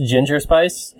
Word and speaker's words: Ginger Spice: Gender Spice. It's just... – I believe you mispronounced ginger spice.